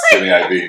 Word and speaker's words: Jimmy Iovine: I thought it was Iovine. – No, it's Jimmy Iovine. – It's spelled Iovine. – I know. It's Jimmy Iovine.